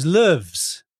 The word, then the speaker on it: Loews: I do, actually, because until I got there, I didn't know how to pronounce it. So it was Loews, L-O-E-W-S.